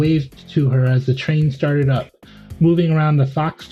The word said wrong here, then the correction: faux: toy, or I don't know, how's it pronounced? F-A-U-X? – Faux? Yeah, faux. – Faux. It's faux. – Faux. Yep, faux.